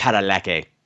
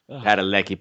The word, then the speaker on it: Padalecki: Padalecki. – Oh, God. Stop that. – Padalecki, Padalecki.